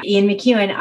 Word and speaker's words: McEwan: Yeah, Ian McEwan.